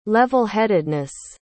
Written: level-headedness

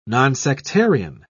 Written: nonsectarian

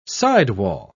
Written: sidewall

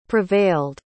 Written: prevailed

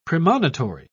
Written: premonitory

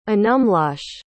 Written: anumloche

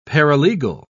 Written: paralegal

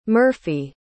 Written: murphey

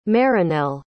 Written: Marinel

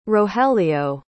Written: rogelio

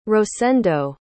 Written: rosendo

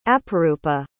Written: aparoopa

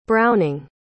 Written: browning

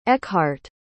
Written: eckhart

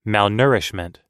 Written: malnourishment

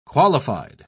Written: qualified

